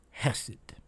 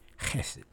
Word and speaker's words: chesed: Well, in the Hebrew, that is a word called, or it is a word, chesed. Pardon my pronunciation, but chesed. C-H-E-S-E-D.